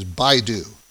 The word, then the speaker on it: Baidu: This is the proper pronunciation is Baidu. B-I-D-U is the symbol. It's spelled B-A-I-D-U, Inc.